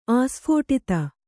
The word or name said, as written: ಆಸ್ಫೋಟಿತ